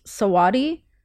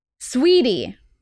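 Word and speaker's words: Saweetie: How do you pronounce this? Sawati? Swati? Sati? Sweetie. Wow.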